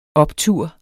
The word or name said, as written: optur